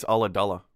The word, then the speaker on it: Ulladulla: It's that YouTube, here's how to pronounce Ooladoola. I was wrong. It's Ulladulla. – Gullah Gullah? – Ulladulla.